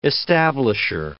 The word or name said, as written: establisher